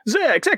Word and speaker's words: Zeck: You got to say it right. Zeck.